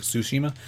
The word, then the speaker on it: Tsushima: Or, Ghost of it probably is pronounced with like an S, like Tsushima, like tsunami, the T silent.